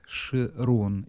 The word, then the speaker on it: Широн: Это же три слоя жизни этого человека, который его зовут Широн, или, ну, Широн, скорее, ударение правильно, его зовут вот так.